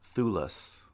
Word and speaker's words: Thewlis: But David, so it's pronounced, I looked it up, Thewlis. – Okay, because it looks like the Willis.